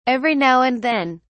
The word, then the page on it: every now and then: British English